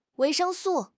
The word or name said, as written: 维生素